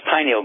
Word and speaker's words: pineal: Well, it's pronounced pineal gland.